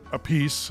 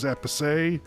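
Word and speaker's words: Appice: Yes, Carmine pronounces it A Piece. His brother is Epice.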